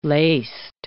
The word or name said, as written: laced